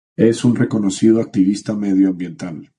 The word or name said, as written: activista